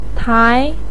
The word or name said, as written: tai2